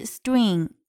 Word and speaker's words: string: 还有要注意的发音是 string，注意这个地方 tr 它有一个浊化，那么实际上它的发音就变成了 dr 的发音，由 d 滑向 r dr。